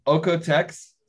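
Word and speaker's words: OEKO-TEX: So Okotex. So it's a German Austrian foundation. So it's that's where the pronunciation comes from.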